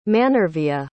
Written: manervia

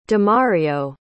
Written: demario

